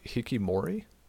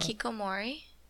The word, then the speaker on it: hikikomori: Hikimori, Hikimori, Hikimori. – Hikikomori.